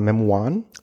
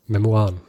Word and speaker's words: Memoiren: Ähm, und zwar, es ist geschrieben für die Nahfeld und der hatte das Ziel, später Memoiren, schreibt man, sagt man Memoiren. – Memoiren.